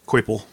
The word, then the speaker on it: Coipel: Coipel. – What? – Coipel. He's French. Oh. It's Olivier Coipel. – Dude, it's Oliver and Company. Whatever. – There's an extra I in there you're not pronouncing.